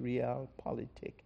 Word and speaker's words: realpolitik: In contemporary terms, that is called realpolitik, R-E-A-L, like real, but it's pronounced realpolitik.